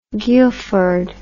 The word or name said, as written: Guildford